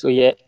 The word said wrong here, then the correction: Ovechkin: Wie spricht man ihn richtig aus? – Ovechkin.